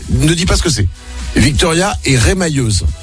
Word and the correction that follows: remailleuse: C'est ce que c'est? – Rémailleuse. – C'est ça, en rapport avec l'émail. – Remailleuse. – On dit, ça se prononce pas comme ça? Remailleuse? – Remailleuse, oui. – Ah, remailleuse, pardon.